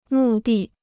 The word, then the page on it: 目的: mù dì